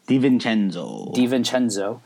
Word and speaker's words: DiVincenzo: DiVincenzo.